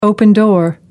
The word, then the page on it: open door: American English